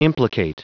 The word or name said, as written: implicate